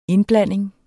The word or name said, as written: indblanding